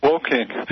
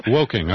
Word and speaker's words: Woking: So what they've done in the town of, it's actually pronounced Woking. – Woking, okay. – Yeah. W-O-K-I-N-G, you have it right,